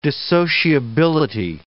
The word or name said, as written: dissociability